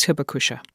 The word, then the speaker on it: Hibakusha: Hibakusha, am I pronouncing that correctly? – Yes. – Okay. What is Hibakusha? – Hibakusha is a survivor of the atomic bomb.